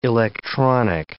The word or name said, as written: electronic